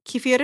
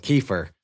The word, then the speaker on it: kefir: Occasionally you can find, what do they call it? Kefir in America, or kefir. How do they pronounce it? People, it's kefir.